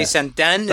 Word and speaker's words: Sandén: Molly Sandén, but there's like an ausento over the E, so I don't know how that's pronounced. – Molly Sandén. – Molly Sandén, is that it? – Yeah, but yeah.